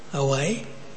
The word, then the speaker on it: Hawaii: Am I pronouncing that right? Hawaii? Yeah, okay.